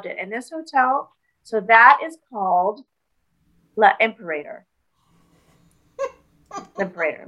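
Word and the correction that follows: L'Impérator: Am I saying it wrong? – Yes. It's okay. – The Imperator? – We'll put it in the show notes so that those of you who actually can read the French can read it. I believe it's L'Imperator.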